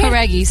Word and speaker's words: Horaghi: Did I spell pronounce it right? – Horaghi, sim.